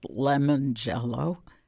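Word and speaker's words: Limongello: It's Lemangello, which is pronounced Lemangello.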